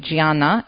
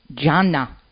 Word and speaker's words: Gianna: She's a co-founder and director of the St. Gianna, and wait a minute, I pronounced it incorrectly. Gianna.